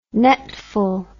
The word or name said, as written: netful